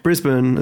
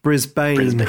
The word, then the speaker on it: Brisbane: And she's from Brizzy or Brisbane, as we say, but as Americans say, Brisbane.